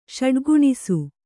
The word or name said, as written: ಷಡ್ಗುಣಿಸು